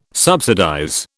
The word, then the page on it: subsidize: British English